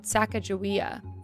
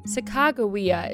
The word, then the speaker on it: Sacagawea: Though sometimes called Sacagawea, Sacagawea is believed to be the correct pronunciation, as there's no soft G in the Hidatsa language.